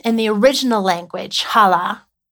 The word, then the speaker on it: kalah: And in that language, the word that he used is pronounced hala, K-A-L-A-H, which means to be complete, to finish, to accomplish, to put to an end, to make perfect.